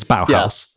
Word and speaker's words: Bauhaus: No, but it is Bauhaus. Yeah. Bauhaus.